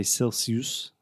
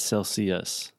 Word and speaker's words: Celsius: Just one thing, Alexia, you pronounced it correctly right now, but a lot of my students I've heard say Celsius, Celsius, but it's Celsius, Celsius. with the schwa sound at the end.